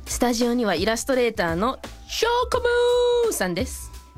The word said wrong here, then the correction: チョコムーン: 言い方合ってます。 – 合ってます。 – 発音はチョコムーンでいいのかな。 – 合ってます。 – チョコムーンさんです。